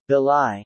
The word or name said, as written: belie